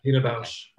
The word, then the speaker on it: Pina Bausch: Yeah, Pina Bausch.